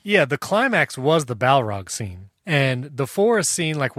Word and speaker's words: Balrog: It's sort of, I think the Mines of Moria, I think the Balrog. – It's pronounced Balrog.